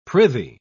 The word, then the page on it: prithee: British English